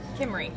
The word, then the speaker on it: Kimmery: I'm here with, and help me with the pronunciation of your name. – Kimmery. – Kimmery, and that's spelled with two Y's.